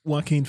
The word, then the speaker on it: Joaquin: So you know that Jackie Phoenix or however you say his name? – Joaquin Phoenix.